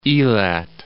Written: Elat

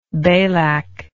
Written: balak

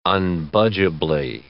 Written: unbudgeably

